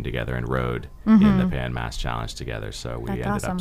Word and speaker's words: Pan Mass Challenge: The Pan Mass Challenge, PMC, Pan Mass Challenge.